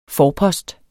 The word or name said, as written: forpost